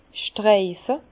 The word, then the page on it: streife: Bas-Rhin; Herrlisheim